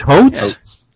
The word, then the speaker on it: Hotz: the, how do you pronounce Kyle's last name, H-O-T-Z? It's hot. It is not. – No, hoots. Really? – It's a long O, like oats.